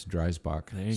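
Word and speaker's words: Driesbach: But we spell it wrong, but we say it right. So it's Dreisbach, spelled wrong, I-E-S.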